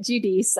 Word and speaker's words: Judice: And pretty much everybody always butchers my last name, but that's how you say it. Judice.